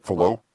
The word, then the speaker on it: Fallot: Fallot. It's French.